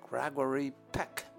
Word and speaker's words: Gregory Peck: 哎呀，原来我们呐，对于这个英文名字的发音啊，不大正确。他原来叫做 Gregory Peck。” 就念成了叽里咕噜屁股了。